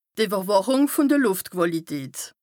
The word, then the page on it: d' Ìwwerwàchùng vùn de Lùftquàlität: Bas Rhin